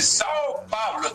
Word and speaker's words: São Paulo: Dice Sao Paulo, ¿está bien dicho así? – Perfecto.